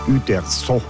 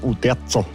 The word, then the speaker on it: Uderzo: So spricht man denn aus, Uderzo. Ich habe immer gesagt, Uderzo.